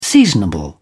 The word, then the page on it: seasonable: British English